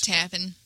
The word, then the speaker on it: Tappan: It's T-A-P-P-A-N. Tappan. Tappan. – Tappan. – Tappan. Who knows? You know those Jersey folks. – Tappan. – There you go. It's Tappan.